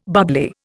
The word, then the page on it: bubbly: Indian